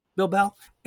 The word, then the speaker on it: Bilbao: Bilbao? – Bilbao.